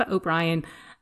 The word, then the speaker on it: O'Brien: and it's Aoife O'Brien, O'Brien, and it's pronounced Aoife O'Brien. And there was one more thing that I wanted to say.